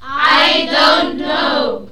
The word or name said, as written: I don't know